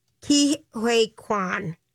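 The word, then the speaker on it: Ke Huy Quan: King Quay, or how do we say his name? His name is Ki-Hui Kwan.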